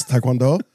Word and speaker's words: taekwondo: Now, we are gathered here today in the Fortress of Solitude, not only to hear Sean pronounce taekwondo incorrectly. It's taekwondo.